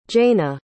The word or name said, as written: jayna